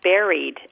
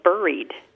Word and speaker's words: buried: Well, my husband and I saw the word buried written, and it occurred to us that we always pronounce it like raspberry. And when we read it, it looks like buried.